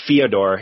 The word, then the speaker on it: Fyodor: But then there's always, you know, fate, or Fyodor, if you want to say his name properly, looming over things.